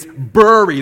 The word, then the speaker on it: bury: The word is bury. You bury the dead. You don't bury them. Like, it's not B-A-R-R-Y, like the name. It's bury. There's a U.